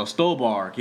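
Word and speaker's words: Stuhlbarg: And I think one thing he does well is with the two characters of Peter Sarsgaard and Michael Stolbe. How the fuck you pronounce this dude's name? Oh, Stolbark, yeah.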